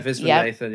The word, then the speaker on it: Viswanathan: And then so her friend Kayla, who is played by Geraldine, and I do hope I'm saying this correctly, Viswanathan. – I think that's right, yeah, Viswanathan, yeah.